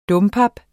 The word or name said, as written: dompap